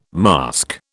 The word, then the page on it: mask: British English